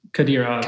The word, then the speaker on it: Kadyrov: Just to maybe give some people at home a reference, I might butcher his name, Ramzan Kadyrov? – Kadyrov, yeah.